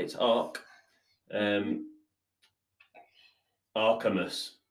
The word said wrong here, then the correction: Arceus: Oh, that is so, so close. It's Arceus. – Arceus, not Archimus.